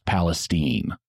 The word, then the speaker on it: Palestine: And yes, Palestine, Texas is pronounced Palestine.